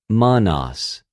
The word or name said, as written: μονος